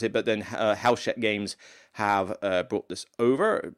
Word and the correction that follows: Hachette: Actually, let me see if I can pronounce that properly, because that was not good. Gigamic Games, G-I-G-A-M-I-C, how else would you pronounce it? But then Hachette Board Games is the people who I think have brought it over.